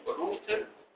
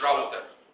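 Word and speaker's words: router: اللي فيكوا عنده في البيت روتر بتاع انترنت، وعلى فكره على فكره ليه اسم روتر مش راوتر، اسم راوتر خلاص عشان كل مصر بتنطق الكلمة دي غلط روتر مش راوتر كلمة راوتر دي لو قلتها قدام اي حد بره مصر ما حدش يعرف انت بتقول ايه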